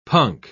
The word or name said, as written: punk